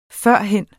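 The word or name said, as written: FØRHEN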